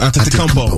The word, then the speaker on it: Antetokounmpo: The emergence of Giannis Antetokounmpo. – I can say it twice. – Antetokounmpo, Antetokounmpo.